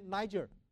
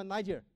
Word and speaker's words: Niger: 这个地方要念 Niger，这个地方要 Niger 啊，不能念你心里想的那个字，不能念了哈，那念出来会得罪人的哈。